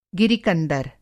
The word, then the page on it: गिरि-कंदर: Hindi